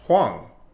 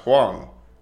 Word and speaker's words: Hosang: But my name is not pronounced that way. – How is it? – It's actually pronounced Huang. – What? – Yeah. – It's pronounced Huang.